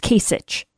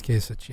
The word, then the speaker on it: Kasich: Kasich. I always say it wrong. It's Kasich. – I believe it is Kasich, yes.